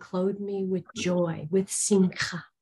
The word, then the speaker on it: simcha: In the Hebrew, the Old Testament word is spelled S-I-M-C-H-A, and that's pronounced simcha with a guttural ch at the end.